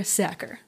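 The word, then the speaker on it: Sachar: I had to look up how to pronounce his last name because I wasn't sure, but according to him, it rhymes with cracker. So Cracker Sacker, right?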